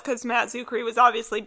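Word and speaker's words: Czuchry: Yeah, the C is silent. – Oh. I was always saying, like, church key or something.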